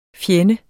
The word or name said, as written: FJENDES